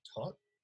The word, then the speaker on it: Toht: I think his I think his name is pronounced Tot T O H T.